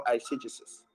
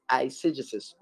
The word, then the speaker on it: eisegesis: Now we have something we call exegesis, and we have something we call eisegesis. Okay, exegesis, let me spell it for you. E-X-E-G-E-S-I-S, exegesis. Then the second word I said is eisegesis or eisegesis. E-I-S-E-G-E-S-I-S, exegesis and eisegesis.